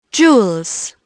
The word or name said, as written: jewels